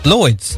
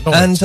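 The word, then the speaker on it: Lloyds: The rhymes with Boyd's. – Yes. – Lloyd's. – What? Yeah, Lloyd's.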